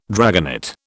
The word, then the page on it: dragonet: British English